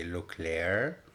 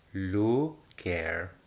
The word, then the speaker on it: Le Caire: But so many people cannot pronounce it right, so either I say Le Caire, that's what I say, Le Caire, so Iman Le Caire, yes.